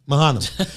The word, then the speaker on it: Mahanaim: Mahanaim.